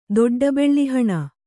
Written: ದೊಡ್ಡಬೆಳ್ಳಿಹಣ

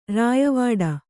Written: ರಯ್ಯವಡೆ

